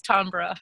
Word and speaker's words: Tambra: It's spelled Tambra, and my last name's Harck, Harck. And you get extra special bonus points if you pronounce it beautifully like Renee did today, which is Tambra Harck.